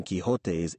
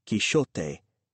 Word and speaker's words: Quixote: Portuguese also uses a sh sound rather than a h sound for the X or J in the middle of Don Quixote's illustrious name. Cervantes himself would probably have said Quixote in the Spanish of his time. For the purposes of this text, the recommended pronunciation is the elegant French Quixote, for reasons which the text itself will make clear. But, gentle listener, suit yourself.